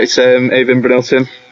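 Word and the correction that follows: Brynildsen: We love pronunciations on this programme, don't we? Someone please shout it. – Bryn Nielsen. Yeah, Bryn Nielsen.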